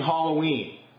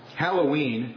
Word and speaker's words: Halloween: In an article published recently, I believe the 28th of October, somewhere around there, on the American Vision website called Concerning Halloween. And apparently this article's had very wide reading. He writes this. Halloween, not Halloween, I've been mispronouncing it myself, is simply a contraction for All Hallows Eve.